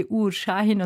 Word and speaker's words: Uğur Şahin: Also er heißt Uğur Şahin, also man schreibt es Ugur, aber das ist ein weiches G, Uğur Şahin und sie heißt Özlem Türeci, aber sie hatten sie richtig ausgesprochen.